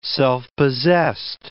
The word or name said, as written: self-possessed